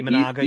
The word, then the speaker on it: Imanaga: And I would look at Imanaga, and I'm not sure if I'm pronouncing it correctly. – Imanaga, yep.